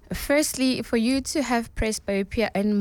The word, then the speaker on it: Presbyopia: Presbyopia. – Presbyopia.